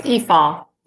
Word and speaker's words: Efaw: Yes, Efaw.